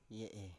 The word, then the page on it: ye7e: Biak